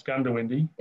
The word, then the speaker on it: Goondiwindi: It's O, but it's pronounced with a U, so it's Goondiwindi.